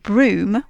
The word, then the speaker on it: Brougham: Yes, so Lady Anne Clifford is connected with two of English Heritage's most spectacular ruined castles, Brough, which is spelt B-R-O-U-G-H, and Broom, which is spelt B-R-O-U-G-H-A-M, and they're both in Cumbria, and they're both very much well worth a visit.